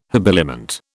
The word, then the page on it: habiliment: British English